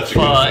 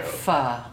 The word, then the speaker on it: Pho: Service is selling, and selling is service, and that was the problem at Super Bowl Pho in Irvington. – You said it right. Pho.